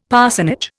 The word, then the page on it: parsonage: Indian